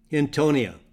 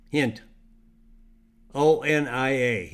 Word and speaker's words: Hintonia: So if you want good blood sugar levels, Hintonia. That's spelled H-I-N-T, hint. O-N-I-A, Hintonia.